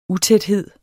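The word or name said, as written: UTÆTHEDS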